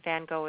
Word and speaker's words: Van Gogh: In the UK, Van Gogh is pronounced Van Gogh.